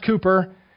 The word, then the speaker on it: Cowper: As William Cooper spelled Cowper, C-O-W-P-E-R, pronounced Cooper, as he said, you know, the bud is bitter, right?